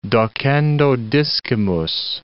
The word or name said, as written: docendo discimus